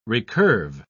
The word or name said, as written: recurve